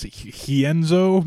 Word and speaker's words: Rienzo: I think there's a special pronunciation to this name because it's a Brazilian name. So I think people said it was technically pronounced Hienzo, maybe? – Yes, I don't know. – With the H.